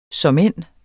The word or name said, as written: såmænd